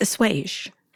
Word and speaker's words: assuage: Did you say assuage? It's assuage.